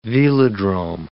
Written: velodrome

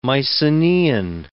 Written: Mycenaean

